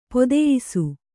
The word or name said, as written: ಪದಯಿಸು